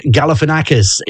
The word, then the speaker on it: Galifianakis: Zach Galifianakis is how it's pronounced. It's spelt Galifianakis, which it should be pronounced as, because he's Greek.